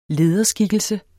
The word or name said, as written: lederskikkelser